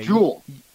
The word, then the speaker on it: Juhl: It's capital J-U-H-L on the pronunciation guide. – Okay. – Jewel.